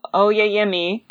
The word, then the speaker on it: Oyeyemi: This is an episode about Helen Oyeyemi. I looked it up. That's how you say it. You were right.